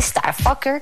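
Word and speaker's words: STRFKR: Es de Starfucker y este sí se escribe totalmente sin vocales. STRFKER R, que si lo pronunciamos tiene que decirse Starfucker, que pues no les voy a decir qué quiere decir, se lo imaginan seguramente.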